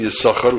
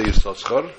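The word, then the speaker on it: Yissachar: And since we only pronounce it with one sin, Yisachar, we don't say Yisachar.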